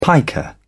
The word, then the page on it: piker: British English